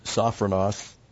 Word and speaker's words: sophronos: The word sophronos, got a long O there, really, sophos, it's talking about thinking.